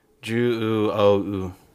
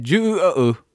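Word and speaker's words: Juuou: Juu-o-u. – Juu-o-u. That's exactly how you say it. Juu-o-u.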